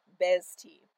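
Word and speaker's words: Behzti: I'm probably mispronouncing it slightly just because I don't speak this language, but I believe it is pronounced Bezti, and it means dishonor.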